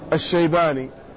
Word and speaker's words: الشيباني: قال ورابعها أي رابع هذه الأسماء التي هي متشابهة في التي هي متشابهة وبينهما تشابه، قال أبو عمرو الشيباني بفتح المعجمة وسكون التحتية ثم موحدة الشيباني. وهكذا أبو عمرو السيباني كذلك لكنه بمهملة. فالأول جماعة كوفيون ومنهم سعد بن إياس أبو عمرو الشيباني، فهذان الاسمان اتفقا في الكنية حيث كل يسمى بأبي عمرو يكنى بأبي عمرو.